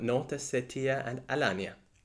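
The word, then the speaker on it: North Ossetia–Alania: Her name is Vlada and she's from North Ossetia and Alania.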